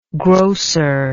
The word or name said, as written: grosser